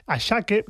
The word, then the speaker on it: Asake: Por cierto, el tema de los nombres Ashake, que se escribe Asake,